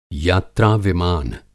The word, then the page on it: यात्रा-विमान: Hindi